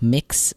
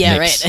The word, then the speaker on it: Mx: I wish that I had at least introduced, because another kind of prefix, I guess, that I would go by is MX, which is like mix, which is like a gender neutral kind of. – You actually say it as mix? – I'm pretty sure. I've actually never heard it pronounced, but yeah. – Well, we heard it here first.